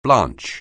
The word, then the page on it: blanch: anglais